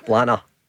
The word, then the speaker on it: Blantyre: When I say Blantyre, what's the official? Blanner. – Blanner, right. – Okay, the T is silent. There's quite a few letters in it silent, actually.